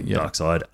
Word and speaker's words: Darkseid: Well, it's spelt seed, but it's actually Darkseid.